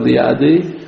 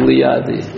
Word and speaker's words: Liadi: Rav Shneur Zalman of Liadi. Miladi, no? No, I said it right. – I'll say it again. Rav Shneur Zalman of Liadi.